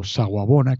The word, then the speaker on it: Sawubona: Y os recuerdo, Saguabona, que se escribe S-A-W-U B-O-N-A, repito, S-A-W-B-O-N-A, Saguabona,